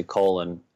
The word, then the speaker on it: Colan: And I've got a buddy who, uh, Dave, Dave, his last name is actually Colon, um, but you know, it's spelled C O L A N.